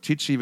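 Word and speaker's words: Tichy: äh Brian Ticky äh, oder Tichi wird es ausgesprochen,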